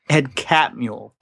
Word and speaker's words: Catmull: Which is a similar setup that you have with John Lasseter and Pixar and Ed Gutnall. Did I say his name wrong? – Ed Catmull.